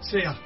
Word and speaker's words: Sia: Sia.